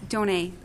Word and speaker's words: Donay: It's a French name. It's Donay. – Donay.